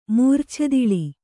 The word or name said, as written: ಮೂರ್ಛೆತಿಳಿ